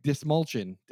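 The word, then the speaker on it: Dastmalchian: David Dashmalodon. Yeah, say his name? – Dismalchin. Dismalchin, that's how you pronounce it.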